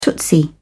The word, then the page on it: tootsie: British English